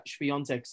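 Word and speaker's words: Świątek: So Iga Swiatek, and she actually corrected everybody. It is Iga Swiatek.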